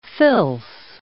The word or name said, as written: fils